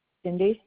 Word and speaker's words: Cindy: Cindy.